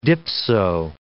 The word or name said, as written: dipso